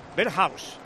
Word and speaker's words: Berghuis: ¿Se pronuncia Berghuis o Berghuis? – Berghuis o Berghuis. – Yo creo que es Berghuis, ¿no? – Berghuis. – ¿Ves? Berghuis. – Berghuis. – Berghuis. Lo había dicho el amigo Sander.